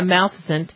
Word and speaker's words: Maleficent: Yeah, Maleficent.